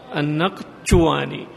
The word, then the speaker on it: النقشواني: فالقاف هذه تنطق قاء، يعني تنطق بحرف بين القاف والكاف، ما تقول النق، تقول النق بحرف بين الكاف والقاف، وهو ليس من حروف العرب، وإنما من حروف اللغات، بعض اللغات الأعجمية. والشين هذه تضغط عليها قليلا لأنها جيم فارسية، النقشواني هكذا بحرف بين الجيم والشين،